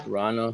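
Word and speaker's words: Vrána: With the 3-year deal, so Rana, I think it's Rana or Reina. The V is silent. I know that.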